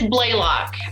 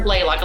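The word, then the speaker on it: Blalock: So it's Blaylock. It is Heather Blaylock. A lot of people mispronounce it because there's no Y there, but it is Blaylock, Heather Blaylock.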